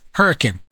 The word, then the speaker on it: Hurricane: There's a town, they pronounce it Hurricane. It's down in southern Utah. It's spelled Hurricane. It's like a cyclone. – Right. – But everybody says Hurricane.